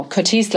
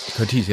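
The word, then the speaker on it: Curtiz: Curtis, glaube ich, wird er ausgesprochen, ne? – Curtis, ja, ja, ja.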